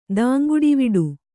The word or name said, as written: ದಾಂಗುಡಿವಡೆ